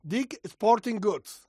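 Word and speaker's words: Dick's Sporting Goods: Porque fíjate, este fin de semana el Champions Tour eh, a ver si lo digo bien, Dick Sporting Goods. Ah, perfecto. Perfecto.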